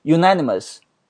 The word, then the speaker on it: unanimous: 那这个单词没有读成 un，它读什么？它读 u 啊，unanimous。